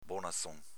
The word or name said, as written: bonazon